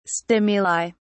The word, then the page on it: stimuli: British English